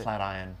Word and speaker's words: Flatiron: Is that how you pronounce it? Flatiron. – Flatiron? – Yeah. – Oh, I've been reading that wrong my entire life.